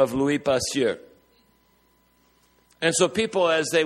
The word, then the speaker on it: Louis Pasteur: His name was Louis Pasteur, as they would have pronounced it in French.